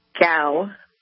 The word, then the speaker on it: Cao: Is that how you pronounce it? Cao or Kao? Emily, is it Kao or Cao? How do I pronounce it? – It's Cao.